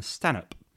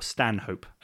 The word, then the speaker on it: Stanhope: Townsend would retire about 6 years in to be replaced by William Stanhope, or Stanhope, as I've been mispronouncing it in many episodes up until now.